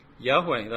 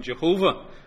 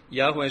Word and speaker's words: Yahweh: Can anyone pronounce God's special name? Yahweh, that's right. That's probably much more accurate than Jehovah. I won't go into where Jehovah comes from. Yahweh is probably more accurate.